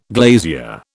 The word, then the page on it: glazier: British English